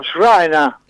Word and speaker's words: Schreiner: Ah, bueno, es algo así como Schreiner, podríamos decir.